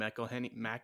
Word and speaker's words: McElhenney: McElhenney.